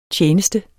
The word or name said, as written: TJENESTER